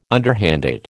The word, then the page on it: underhanded: American English